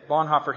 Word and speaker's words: Bonhoeffer: Dietrich Bonhoeffer or Heffer? Hoffer? Okay, Bonhoeffer.